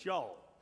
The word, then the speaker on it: y'all: And y'all is not two words, and y'all is not two syllables. It's y'all.